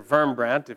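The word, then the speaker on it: Wurmbrand: Pastor Richard Wurmbrandt, or Wurmbrandt, if you were to say it correctly, was a Romanian Lutheran minister who spent about 14 years in communist prison in Romania.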